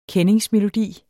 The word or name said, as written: kendingsmelodi